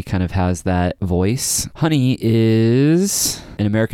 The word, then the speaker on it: Hunny: By Honey, I mean it's spelled H-U-N-N-Y, and Honey is on Epitaph Records, and they put out a fantastic record that I think fell under the radar.